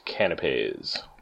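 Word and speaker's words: canapés: And pretty sure they're pronounced canapes. – You are correct.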